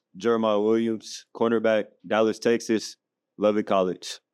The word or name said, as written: Jeremiah Williams